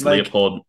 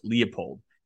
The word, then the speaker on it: Leipold: Is, and I honestly don't know because I've heard different people pronounce it different ways, and I pronounce it Leopold. Is it Leipold? – I think so.